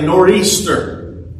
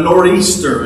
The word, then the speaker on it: nor'easter: Now, you may have heard of a nor'easter, which is really just a mispronunciation of a northeastern. But a nor'easter, and that's what the old timers that I grew up with always called it, a nor'easter.